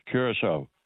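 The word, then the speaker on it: Curaçao: And I don't know if I pronounced it right, but I thought it was Curacao.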